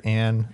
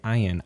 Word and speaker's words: Ayn: I've always called her Anne. I don't know. – I always thought it was Anne until I was listening to another podcast this last week, and all I kept was hearing Ayn, Ayn, Ayn. I'm like, have I been saying it wrong the whole time? – It's close enough, I guess.